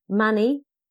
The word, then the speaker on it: Mani: Mani, rhymes with nanny, stress on the first syllable.